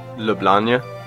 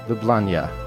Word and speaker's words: Ljubljana: How do you pronounce it? – Ljubljana. – Isn't that what I just said, Ljubljana? I thought you had like a Y in there or something. – Ljubljana. – Ljubljana, okay. – I guess I pronounce it with a Y in the end part.